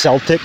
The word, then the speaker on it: Celtic: And I said Celtic, and then he was like, "It's pronounced Celtic."